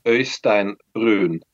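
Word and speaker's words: Øystein Brun: Is that how I say your name? From Borknagar? – Yeah, that's okay. That's okay. It's pretty close. – Can I get the perfect pronunciation? – Øystein Bruun. – Okay. Øystein Bruun. – Yeah, we have this in Norway, in Norwegian language, that's only in Norway, you know, but that's a difficult sound, I guess, for a lot of people, but it's okay.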